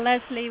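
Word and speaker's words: Lesley: So when you look for the Dr. Leslie Phillips .com website, just remember to spell Leslie with an E-Y and Phillips with two L's.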